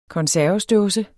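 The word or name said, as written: konservesdåsen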